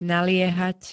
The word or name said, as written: naliehať